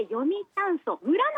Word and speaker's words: 読谷村: はい、沖縄本島のちょうど真ん中、そして西寄りに位置する、読む谷の村と書いて読谷村なんですが、うん、皆さん小さなこじまりとしたイメージではなくて、なんと人口約 4 万 2000 人、日本一人口の多い村となっています。